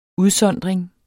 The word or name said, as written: udsondring